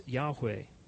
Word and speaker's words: Yahweh: No one is completely sure how it's to be pronounced, but we've pronounced it as Yahweh.